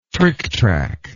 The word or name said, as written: tricktrack